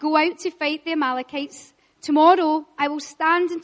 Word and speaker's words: Amalekites: The Amalekites, said it right, the Amalekites defeated.